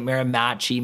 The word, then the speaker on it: Miramichi: Miramichi is an indigenous name, you know, so I don't know if there are people have trouble pronouncing it. Miramichi, Miramichi.